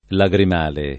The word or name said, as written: lagrimale